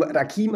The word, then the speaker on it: Rakim: Ich habe ihm, hab dem Rakim habe ich gesagt, pass auf, du bist ein, äh, du bestehst auf deiner jambischen Aussprache, Zweitsilbenbetonung und nicht trochäisch, ja, Rakim.